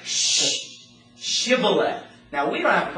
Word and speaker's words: Shibboleth: Okay, say Shibboleth. And they're like, sh. They couldn't make that sh sound.